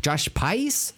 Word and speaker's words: Pais: Josh Pais? P-A-I-S? Is how you pronounce his name? Uh-huh.